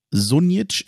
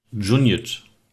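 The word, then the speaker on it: Šunjić: Und auch bei Sunic, ich spreche ihn jetzt mal so aus, wie man ihn liest, ist es wohl so, dass der eigentlich auch anders ausgesprochen wird. Du konntest das eben so schön aussprechen. Das ist wie ein Sch. Sag's nochmal. Junic. Ja, perfekt.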